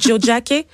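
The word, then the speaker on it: Tiohtià:ke: Euh, puis oui, ça s'appelle Jojake et c'est comme ça qu'il faut le prononcer. Moi, j'ai dit Tiotake. Donc, Jojake, ouais.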